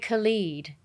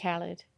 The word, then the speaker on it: Khaled: You called him DJ Khalid and it's DJ Khaled.